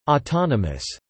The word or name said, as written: autonomous